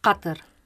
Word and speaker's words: Qatar: Qatar. – Pardon? – Qatar. – Qatar. – Qatar. – Qatar. – No, that means danger. Qatar. – Qatar. Harbour. – Like hotter. – Hotter. – No, Qatar.